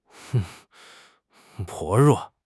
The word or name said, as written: 薄弱